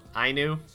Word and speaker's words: Ainu: Ainu.